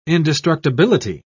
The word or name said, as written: indestructibility